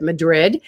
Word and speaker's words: Madrid: So Stella is in Madrid, New Mexico, or some people pronounce it Madrid, with KMRD radio station.